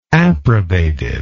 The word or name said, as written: approbative